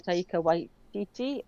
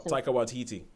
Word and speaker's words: Taika Waititi: Yes, this is a film by Taika Waititi. I think that's how you say it. – Taika Waititi.